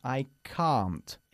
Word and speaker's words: can't: Sin embargo, cuando expresamos este verbo en negativo, decimos I can't. El mismo sonido que nuestra palabra casi para coche.